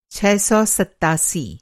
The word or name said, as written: छह सौ सत्तासी